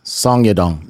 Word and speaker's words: Song Yadong: Song Yadong.